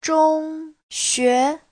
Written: zhong1xue2

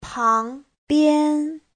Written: pang2bian1

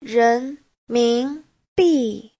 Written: ren2min2bi4

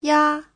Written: ya5

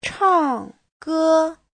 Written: chang4ge1

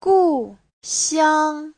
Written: gu4xiang1

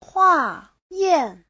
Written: hua4yan4

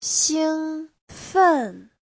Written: xing1fen4

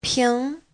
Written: ping2

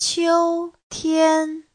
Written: qiu1tian1

